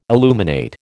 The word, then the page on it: illuminate: American English